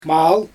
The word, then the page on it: maal: Xyzyl